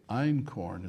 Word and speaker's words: Einkorn: By the way, Einkorn is spelled E-I-N, which in German means one. Ein, zwei, drei, vier, fünf, sechs, sieben, acht, neun, zehn. Yeah. Ein is one, and corn is spelled K-O-R-N. I think that's how I used to spell it in school. – Well, it sounds like that corn.